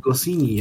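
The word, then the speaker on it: Goscinny: wie spricht man den aus? Gossini, ja.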